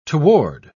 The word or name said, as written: toward